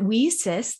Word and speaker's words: WiCyS: Fun fact is that we often go by our acronym WSIS, W-I-C-Y-S, and we pronounce it WSIS like we sisters because we are a global cyber sisterhood.